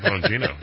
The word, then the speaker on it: Bongino: Dan Bongino. Yeah, was that the right way to pronounce it? – No, you said it right.